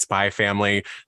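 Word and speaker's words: Spy × Family: So for those of you furiously typing in Libby, it's Spy X Family, but you just pronounce it Spy Family.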